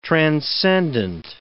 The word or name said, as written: transcendent